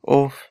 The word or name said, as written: of